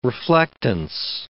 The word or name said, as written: reflectance